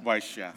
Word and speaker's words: Vaishya: Vaishya? – Vaishya.